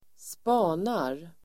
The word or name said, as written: spanar